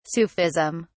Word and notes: sufism: British English

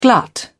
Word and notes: glut: British English

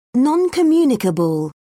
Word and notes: non communicable: British English

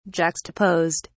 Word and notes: juxtaposed: British English

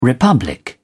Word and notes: republic: British English

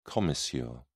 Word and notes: commissure: British English

